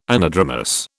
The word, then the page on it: anadromous: British English